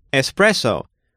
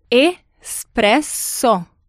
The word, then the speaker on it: espresso: Espresso. Wow, the pronunciation of that is a little different from what you hear abroad, right? Can you break that down for us one more time? – Espresso.